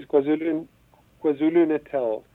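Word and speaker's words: KwaZulu-Natal: Yes, KwaZulu Natal.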